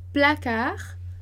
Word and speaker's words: placard: S, T e D a gente não pronuncia no final de palavra, né? Lembrando, carnet, placard, Paris, ok? E em seguida a gente passa para France, France.